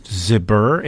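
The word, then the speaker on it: Zbur: His name is Rick Chavez, and I think his last name is pronounced Zibur. It's Z-B-U-R. There should be a vowel between Z and B, but there's not.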